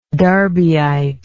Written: darbyite

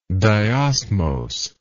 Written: diosmosis

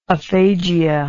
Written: aphagia

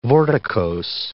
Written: vorticose

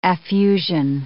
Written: affusion

